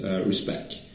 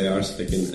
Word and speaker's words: RSpec: Well, RSpec is, as I'm trying to introduce it nowadays, the pronunciation is respect. – Respect. – Like Allergy says. But I haven't quite gotten into it, so I'll probably say RSpec and respect.